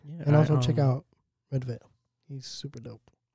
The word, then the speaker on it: Redveil: There's an artist called Red DeVille, R-E-D-V-E-I-L. – Red Veil? – Red Veil. Is it Red Veil? That's how you pronounce it?